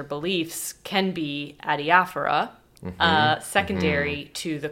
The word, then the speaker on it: adiaphora: Adiaphora? – Yes.